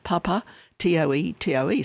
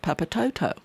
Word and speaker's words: Papatoetoe: This is, I'm just digressing, years ago, and it's spelt Papa, T-O-E-T-O-E. So I call it Papatoto. And they just laughed at me because it's Papatoetoe, because they pronounce each vowel.